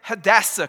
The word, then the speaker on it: Hadassah: And that's when Mordecai and Hadassah come on the scene.